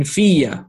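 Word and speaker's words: fiya (فِيَّ): In reality, it's pronounced and written as this, as with a shadda and a fatha, fiya, like that.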